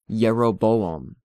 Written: Jeroboam

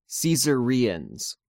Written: Caesareans

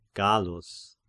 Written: Gallus

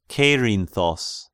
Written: Kerinthos

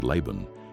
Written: Laban

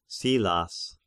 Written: Silas

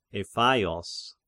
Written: Ephaios

